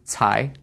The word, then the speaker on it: Cai: Okay, and the preferred spelling now, Hart spells it another way, but it's spelled C-A-I, which is Tsai, and then Lun is L-U-N.